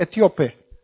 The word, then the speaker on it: etíope: Eh, había aquel Felipe que hablaba con el Etiopio, Etiopía. ¿Cómo se dice? Etíope. Una persona de Etiopía, etíope.